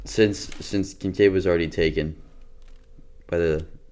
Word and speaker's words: Kincaid: Kincaid, that's what I thought.